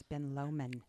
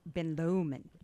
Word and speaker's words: Ben Lomond: And just a fun little, as we were talking about how to pronounce, it's Ben Lomond. – Oh, is that it? Okay. – You're in Utah. – I'm in Utah now. – Ben Lomond.